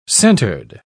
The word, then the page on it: centred: British English